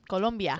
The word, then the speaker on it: Colombia: Uh, Columbia jersey. Columbia. Am I saying that right? Columbia. Yeah, Columbia. Because I know um, Colombia. Oh, you say Colombia.